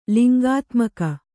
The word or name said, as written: ಲಿಂಗಾತ್ಮಕ